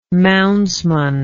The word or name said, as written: moundsman